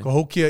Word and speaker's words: Cahokia: Cahokia, Illinois. – Cahokia, Illinois.